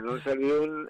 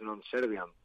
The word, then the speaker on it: Non Serviam: Bueno, pues el de Non Servium es Non Servium, que realmente es una errata del latín, porque bien pronunciado sería y bien escrito es Non Serviam, es con A.